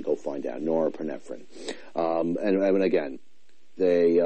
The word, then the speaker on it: norepinephrine: The SSNRI works on the serotonin and the nor- norepinephrine is actually how it's pronounced.